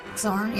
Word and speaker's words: Xorn: I may be pronouncing it wrong because it's Xorn. Xorn, maybe. – Zorn, yeah, I just think of Zorn.